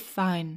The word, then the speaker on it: Fiennes: F-I-E-N-N-E-S. Rafe, fine.